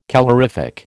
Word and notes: calorific: American English

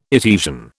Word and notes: Etesian: British English

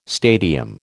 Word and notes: stadium: American English

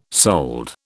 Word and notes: sold: British English

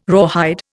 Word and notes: rawhide: Indian

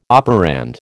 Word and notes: operand: American English

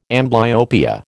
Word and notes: amblyopia: American English

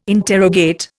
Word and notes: interrogate: Indian

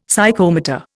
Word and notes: Psychomotor: Indian

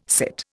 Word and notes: sit: Indian

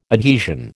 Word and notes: adhesion: American English

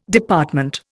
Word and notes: Department: Indian

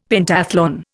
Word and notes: Pentathlon: Indian

